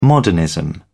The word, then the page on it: modernism: British English